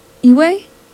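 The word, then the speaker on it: Ewe: I believe it's pronounced Iwe and French.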